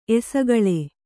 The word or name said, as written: ಎಸಗಳೆ